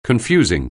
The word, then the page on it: confusing: anglais